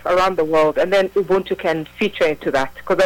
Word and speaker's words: Ubuntu: And you had stated that you wanted to talk about Ubuntu. Is that, am I saying that correctly? – It's Ubuntu. – Ubuntu.